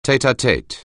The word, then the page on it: tête-à-tête: anglais